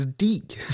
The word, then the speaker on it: DiC: I'm like, it's Deek. it's, it says it. It says Deek. Is that how I should have pronounced it? Like, uh, Deek.